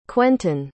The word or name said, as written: cwentun